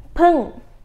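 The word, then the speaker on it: pưng (ผึ้ง): nói tới đâu rồi à con ong đọc là pưng pưng pưng các bạn nhớ nhớ nha búng hơi ra pưng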